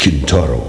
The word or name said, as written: Kintaro